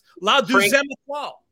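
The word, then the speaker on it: La Deuxième Étoile: I don't know how to pronounce the 2 La Demoiselle Etoile. – This was one of our big winners on opening day, Chad. – Yeah, that's right. – Frank's call 8,000 times. La Deuxième Etoile.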